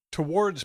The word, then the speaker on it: towards: Towards begins with a schwa sound and has an R sound, towards. You can think of the word war is inside the word, towards.